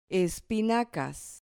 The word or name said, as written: espinacas